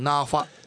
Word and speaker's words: 那覇: 那覇をナーファと発音され